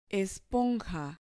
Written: esponja

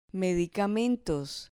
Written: medicamentos

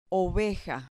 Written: oveja

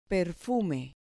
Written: perfume

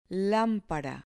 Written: lampara

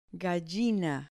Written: gallina

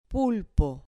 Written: pulpo

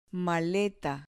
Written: maleta

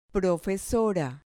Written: profesora

